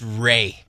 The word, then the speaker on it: Ralph: I think it's pronounced Ray.